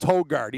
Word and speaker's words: Højgaard: But first-round leader, Rasmus Højgaard with the silent J. Rasmus, on the FanDuel site, it'll look like Højgaard, but it's Højgaard.